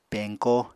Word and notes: peñko: Apatani

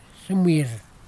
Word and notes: sɨmir: kwamera

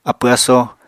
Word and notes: apw-asó: Apatani

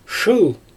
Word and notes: ʃɯɯl: Xyzyl

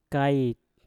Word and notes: kait: Mpur

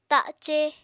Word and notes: ta7ce: apatani

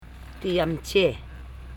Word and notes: jamche: jam.che

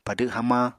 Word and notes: padw hamañ: Apatani

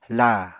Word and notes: laa: Apatani

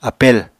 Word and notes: apel: Apatani

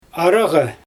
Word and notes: aryGa: Xyzyl

